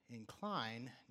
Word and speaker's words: incline: But when you put the emphasis on the second syllable, incline, now we're talking about a different word, and that's the word I want to talk about this morning.